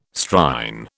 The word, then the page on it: Strine: British English